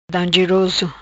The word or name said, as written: Dangeroso